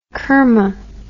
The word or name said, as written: kerma